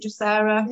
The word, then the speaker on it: Drosera: So we've been using pulsatilla this week, and also Drosera. Is it Drosera? – Yeah.